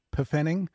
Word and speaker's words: Pfennig: Isn't it pronounced Pfennig? – Yeah, Pfennig.